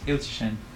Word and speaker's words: Ilchyshyn: My last name is Ilchishin. – Okay, how do you spell that? – I-L-C-H-Y-S-H-Y-N.